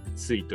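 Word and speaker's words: 翠: あそうです、あの羽羽に卒業の卒と卒と書いて、スイと読むんですけど、あれが漢字のカワセミという読むので、そこからも実は取ってます。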